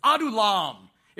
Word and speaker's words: Adullam: It's Adullam.